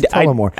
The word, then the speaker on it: Tullamore: There's a U in the word. It's Tullamore.